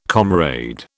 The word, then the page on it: comrade: British English